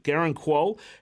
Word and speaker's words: Garang Kuol: Garang Kuol, is that how you say it? – Yeah, Garang Kuol has it's just come out, according to BN Sports, he signed a four-year deal with Newcastle United in the Premier League.